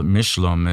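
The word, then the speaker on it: Mechoulam: But the father of modern medicine, modern cannabis, came out of Israel, and he's still Dr. Raphael Mishlam is the proper pronunciation.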